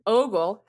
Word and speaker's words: ogle: So here she says, the word in question, ogle, has a long O, like ogre or okra, not like orca, octopus, or oolong.